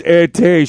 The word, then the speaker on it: Ittee: I-T-T-E-E. – Like Itty Bitty. – Itty. Oh, like Itty Bitty? Okay. – Yeah, like, not idiot. She's Itty. – There you go. – Her name is Itty.